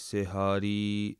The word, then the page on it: siharee: Punjabi